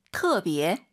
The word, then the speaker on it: 特别: 근데 발음하실 때 터 아니고요. 트어라고 보시면 됩니다. – 特别.